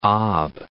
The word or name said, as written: Ob'